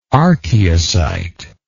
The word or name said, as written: archaeocyte